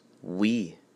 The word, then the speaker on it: oui: Y-E-S. What's another word for yes? Oui. En français, it's oui. O-U-I. Oui. If we say that phonetically, it's W-E.